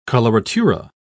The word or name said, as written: coloratura